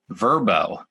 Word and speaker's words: Vrbo: Well, we're excited to hear about your experience, and I think I'm saying this right Verbo with formerly known as VRBO. – That's correct.